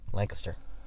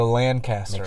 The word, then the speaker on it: Lancaster: Lancaster. – I used to say Lancaster. – Make sure you say it right.